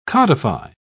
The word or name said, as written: codify